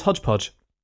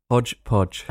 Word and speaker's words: hodgepodge: So would you pronounce that hodgepodge or hotchpotch? – Hotchpotch. – Hotchpotch. Well, may you sneer at that. But just know that that is the traditional British variant of the phrase.